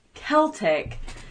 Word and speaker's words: Celtic: And then we had a little argument about Celtic versus Celtic. – Yeah. So it's technically pronounced Celtic. The Boston Celtics.